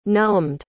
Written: numbed

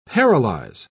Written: paralyze

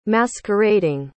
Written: masquerading

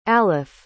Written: alef

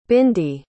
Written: bindy

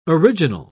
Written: original

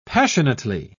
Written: passionately

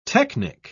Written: technic

